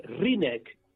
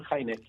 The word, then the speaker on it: Hynek: Y fíjate, yo sé que a ti te encanta, ¿no?, la historia de Hynek, Joseph Allen, Rinek, atención, ¿eh?, la pronunciación cierta, todos saben Hynek, ¿no? – Ahí va.